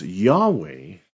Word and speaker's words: Yahweh: And what's interesting is the way that we spell it as Yahweh.